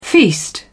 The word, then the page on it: feast: British English